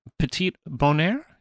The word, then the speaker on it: Petit Bonheur: Well, I have your song, the Petit Bonheur.